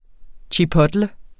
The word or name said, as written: chipotle